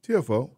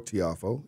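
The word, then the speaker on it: Tiafoe: Francis, is it TFO? – TFO. – I said it right? – TFO or Tiafo, either way.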